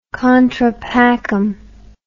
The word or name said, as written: contrapacem